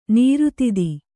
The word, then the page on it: ನಿರ್ಧೌತ: nirdhauta